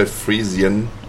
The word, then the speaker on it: Friesian: Friesen is the German pronunciation. – Friesen. – Maybe you would say Friesian then.